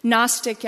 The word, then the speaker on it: Gnostic: Gnostic, I would guess you're familiar with that word, but in case not, it's spelled with a G, G-N-O-S-T-I-C.